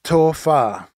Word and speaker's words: tofa: How do you say goodbye in Samoan? – It's tofa.